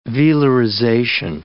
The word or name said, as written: velarization